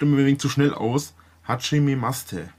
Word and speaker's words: Hajimemashite: Also, die Silben sind Ha-Ji-Me-Ma-Shi-Te. Und das I bei Ma-Shi-Te wird oft verschluckt, also Hajime-Mashite.